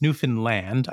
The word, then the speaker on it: Newfoundland: And it's Newfoundland.